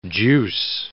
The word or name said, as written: juice